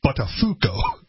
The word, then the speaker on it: Buttafuoco: Yeah, Andy Dick had been put on, he'd like begged to get on air and so they put him on air and he mispronounced Buttafuoco. – But they didn't say it, right? They just came off the air and they look at him and they're like, "Hey, it's Buttafuoco."